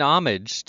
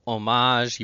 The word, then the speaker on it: homage: It begins with H, but I didn't pronounce the H. It's actually a French word, and you might hear it as homage, you might hear homage, which is how Americans, when they're speaking English, usually say it.